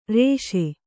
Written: ಋಷಿ

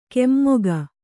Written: ಕೆಮ್ಮೊಗ

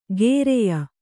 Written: ಗೇರೆಯ

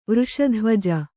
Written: ವೃಷಧ್ವಜ

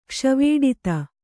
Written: ಕ್ಷ್ವೇಡಿತ

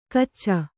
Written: ಕಿಚ

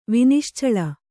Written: ವಿನಿಶ್ಚಳ

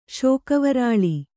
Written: ಶೋಕವರಾಳಿ

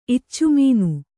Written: ಇಚ್ಚುಮೀನು

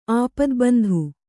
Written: ಆಪ್ತಬಂಧು

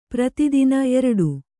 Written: ಪ್ರತಿತುನಿ

